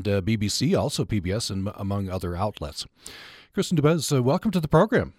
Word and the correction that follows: Dumais: Am I pronouncing your name correctly? – No, okay. Close. It's Dumais. It's French. – Oh, Dumais. Okay, French. Dumais.